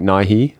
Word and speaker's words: Nighy: I think Bill Nye, the actor, is more like Nighy.